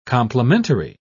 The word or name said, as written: complimentary